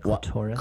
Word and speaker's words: clitoris: a pierced clitoris. Did I say that right? Clitoris? – Well, clitoris, that is correct.